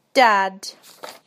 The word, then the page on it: dad: British English